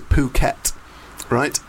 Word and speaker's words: Phuket: Secondly, is the competition was to win a holiday to Phuket, right, in Thailand.